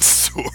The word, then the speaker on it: sword: I hear you pronounce the W in sword. Only in Old English or languages it was derived from was that pronounced. It is now and has been silent for a very long time.